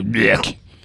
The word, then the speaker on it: Björk: So he's like, Björk. – Yeah, it's kind of, you kind of puke it up. Björk.